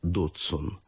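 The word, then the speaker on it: Dodgson: Он произносил его «Дотсон».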